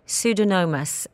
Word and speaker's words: Pseudomonas: Sammy says, my cat has been diagnosed with pseudomonas in her nose and sinus.